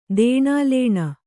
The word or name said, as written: ದೇಣಾಲೇಣ